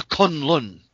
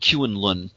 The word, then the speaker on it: Kunlun: So the cover, the most requested story of all, Return to Kunlun. And I remember when I was a kid, I mispronounced it Kuen-Lun.